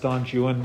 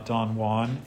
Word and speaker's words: Don Juan: By the way, it is pronounced Don Juan, not Don Juan, if you're reading Byron.